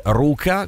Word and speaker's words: Luca: Quindi no, Anna vero, si legge Anna e Luca si legge Ruka, con la e, come se fosse una R, però sono giapponesi e vogliono dire delle cose ben precise.